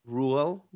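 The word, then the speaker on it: Brühl: Well, I mean, the Russos and the actor Daniel Bruhl, I don't know if I'm saying his name correctly. – I think it's Bruhl.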